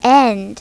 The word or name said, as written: end